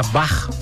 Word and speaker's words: Bach: Nós falamos de Bach, a pronúncia Bach, B-A-C-H, o alemão CH sempre tem esse R.